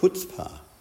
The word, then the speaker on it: chutzpah: Chutzpah is, it's actually, I found out from a Jewish friend, it's actually Jewish in origin, and it's actually pronounced chutzpah, but we call it chutzpah.